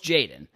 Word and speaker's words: Jadon: So, for example, when I'm talking about it matters for the player specifically, so Jadon Sancho, and I pronounce his name wrong all the time, but I know it's Jadon,